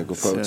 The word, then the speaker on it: guapote: That's how Mike said you pronounce it. Yeah, yeah, guapote. So.